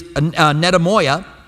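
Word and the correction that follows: metanoia (μετάνοια): or metanoia. Metanoia. I say it's easy to pronounce and then I butcher it.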